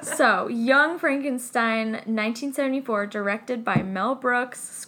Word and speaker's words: Frankenstein: You're putting me on. – No, it's pronounced Frankenstein. – Do you also say Frederick? – No, Frederick. – Well, why isn't it Frederick Frankenstein? – It isn't.